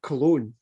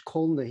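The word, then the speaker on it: Köln: left back for, well, we would say, back in the day, we would have said Cologne, but now I believe it's Colne, you're supposed to pronounce it.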